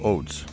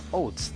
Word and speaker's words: Oates: Yo digo Oates. – Oates, tal cual.